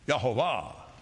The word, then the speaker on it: Yehovah: The Lord of hosts, Yehovah Sabaoth, Yehovah Sabaoth, say it, Yehovah Sabaoth, Englishized as Jehovah. Well, you might as well say it right. Yehovah, glory to God.